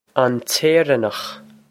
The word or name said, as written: an tÉireannach